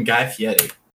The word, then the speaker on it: Guy Fieri: Why do you keep calling him Guy Fieri? – Oh, that's how it's pronounced.